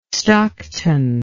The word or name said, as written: stockton